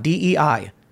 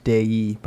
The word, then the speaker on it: Dei: DEI means God. Of God. That's creepy to me. – Yeah. Apparently pronounced Dei, by the way.